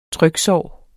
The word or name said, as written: tryksår